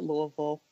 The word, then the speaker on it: Louisville: We call it Louisville. I guess we get lazy with how we pronounce our city, but I've always followed the Louisville Cardinals, men's basketball.